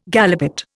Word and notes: galipot: Indian

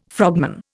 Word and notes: frogman: Indian

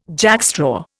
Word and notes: jackstraw: Indian

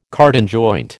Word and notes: cardan joint: American English

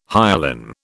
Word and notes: hyalin: British English

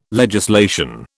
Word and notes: legislation: British English

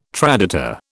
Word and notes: traditor: British English

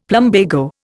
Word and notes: plumbago: Indian

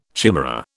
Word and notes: chimera: British English

